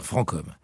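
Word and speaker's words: Franchomme: Tiens, justement, à propos de prononciation, faut-il dire francom ou franchom? Allez, on va dire francom.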